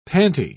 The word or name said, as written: panty